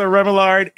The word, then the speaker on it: Remillard: Lisa Remillard is a...